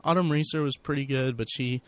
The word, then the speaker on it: Autumn Reeser: But anyway, his character in the movie is, he meets Autumn Reeser.